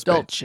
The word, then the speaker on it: Dulce: We still can't figure out how to pronounce it in New Mexico, but it's an Air Force Dulce. – Base. Dulce. This sweet base.